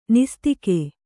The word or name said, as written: ನಿಸ್ತಿಕೆ